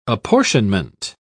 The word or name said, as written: apportionment